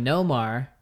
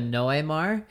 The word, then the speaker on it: Noemar: And that one, his dad actually reached out because I had a pronunciation wrong where I was saying Nomar and instead of Noemar. And so in Spanish, it's the difference of saying Nomar's no ocean, you know, and that's not his name.